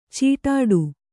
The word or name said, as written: ಛಡ್ಡೆ